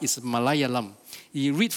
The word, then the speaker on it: Malayalam: I know I sound like Indian, but it is how it's being pronounced. Malayalam.